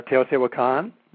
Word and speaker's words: Teotihuacan: And is it Teotihuacan? Is that how you pronounce it? – Yeah, Teotihuacan.